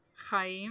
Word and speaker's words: Chaim: Is it a C-H? – Yeah. Chaim. – So, Chaim. You gotta have the Chaim.